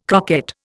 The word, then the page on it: croquette: Indian